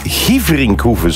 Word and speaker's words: Gieverinkhoeven: Benoît Dorné stuurt, hey Björn, de rally is in Gieverinkhoeven, zo spreek je het uit.